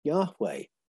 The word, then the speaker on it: Yahweh: but that's because the way we write the holy name, Y H WH. We put vowels in to make it pronounceable, and the H's almost disappear. Yahweh. You go, and this is my thing going back to the roots, go to Proto Northwest Semitic, HH is pronounced.